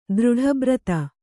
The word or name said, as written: ದೃಢಬ್ರತೆ